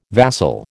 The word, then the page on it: vassal: American English